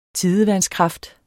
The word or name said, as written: tidevandskraft